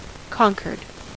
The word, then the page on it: conquered: American English